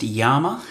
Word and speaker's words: llama: And it's a little bit disconcerting, you know, because I don't have anything with me except for my hat, my llama, pronounced Yama hat, and some water and food.